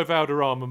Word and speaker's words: Valderrama: Right then, let's go Valderrama Masters.